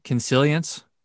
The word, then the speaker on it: consilience: And this is your idea of consilience, if I'm saying that right? – Right.